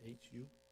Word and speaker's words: Hu: How do we say this name? H-U? – Hu? Yes.